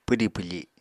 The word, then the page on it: pwdi pwfi: apatani